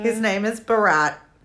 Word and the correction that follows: Barat: His name is Borat. – Borat, yeah. – Like the French spelling, French pronunciation, Borat. – It's B-A-R-A-T, Borat.